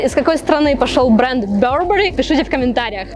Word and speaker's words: Burberry: этот бренд правильно произносится не Барбари, а Burberry.